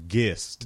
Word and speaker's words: Gist: Is it like GIF? – It's Gist. – GIF? – Gist.